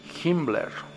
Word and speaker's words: Himmler: Hace rato han de haber escuchado que pronuncié Himmler. Así era como, así también se hacía llamar, o sea, era conocido, Hess o Hitler, ¿ok? No me equivoqué, así dice.